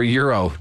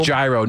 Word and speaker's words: gyro: So I remember euro, not gyro, not like the American gladiator. – Tzatziki on that. No, right. – Not like gyro.